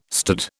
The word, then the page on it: stood: British English